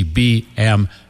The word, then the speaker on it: BMW: The reason technically it should be BMW. V is because in German, the letter W is pronounced like a V. Like you say, Auf Wiedersehen, to say goodbye. If you spell out Wiedersehen, it begins with a W.